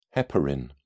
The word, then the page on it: heparin: British English